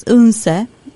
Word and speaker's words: Odense: Actually, Odense is Unse. I think this is how you pronounce it. – Unse. – Yes. Thank you very much.